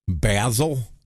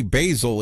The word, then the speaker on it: Basil: I've heard people messing with her last name, calling her Basil and other things, but Tony Basil is the accepted pronunciation according to Google.